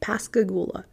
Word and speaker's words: Pascagoula: I think that's how you say it, Pascagoula.